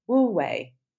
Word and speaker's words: Wu Wei: But Wu Wei, I think that's how you say it. Wu Wei. Sorry if I'm butchering that. – Sounds perfect.